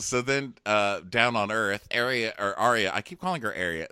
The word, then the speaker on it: Arya: Let me pronounce it properly for everyone. Arya. As your mother would say it.